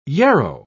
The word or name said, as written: yarrow